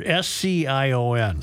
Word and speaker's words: scion: I never knew how to pronounce this word S C I O N. – Scion. The scion